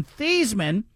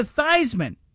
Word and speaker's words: Theismann: We would like to change the pronunciation of your last name from Thiesman to Theismann.